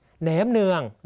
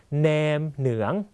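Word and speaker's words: nem nướng: ับพี่จางและอาเทืองต่างก็เป็นคนหวยหวยคือเวนั่นเองนะครับต่อมานะฮะแองเซิร์นว่าแองจุงเดวทิกอันแนมเหนืองนะครับแปลว่าอะไรพี่เซิร์นและนะครับว่าแปลว่าและพี่จุงต่างก็ชอบกินแหนมเนืองนะครับเรียนภาษาเวียดนามห้ามพูดว่าแหนมเนืองต้องออกเสียงว่าแนมเหนืองนะครับ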